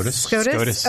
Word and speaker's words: SCOTUS: This is if SCOTUS, is that the right way to say that? – SCOTUS. – Okay. If SCOTUS, and that's Supreme Court of the United States. – Yeah. Supreme Court. – President is POTUS. – Yeah. Okay. I want to make sure I didn't say it wrong. I knew what it meant. I just didn't know how to say it.